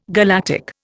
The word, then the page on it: galactic: Indian